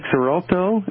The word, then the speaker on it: Xarelto: I take Xarelto.